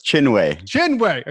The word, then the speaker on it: Chinwe: And you actually wrote, you and I think her name is Dr. Kinway. Did I pronounce that correct? – Yeah, close. Chinwe.